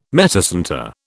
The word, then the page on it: metacentre: British English